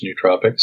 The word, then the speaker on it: nootropics: I haven't, but I have this question all the time about how do you pronounce nootropics? And some people pronounce it nootropics, like Noah in the Ark. Ah. And other people pronounce it nootropics, and I pronounce it nootropics, and I'm the nootropics expert, so I say it's nootropics, so let's stick with nootropics.